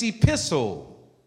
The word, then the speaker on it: epistle: I said, turn to the second epistle of Peter. And this guy said, that's not pronounced that way. I said, how is it pronounced? He said, the T is silent. It's epistle.